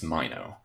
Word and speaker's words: Minot: So one important character in Design for Dying in particular is a director named Lawrence Minot, who, if I'm pronouncing that right, M-I-N-O-T. – That's right, yes.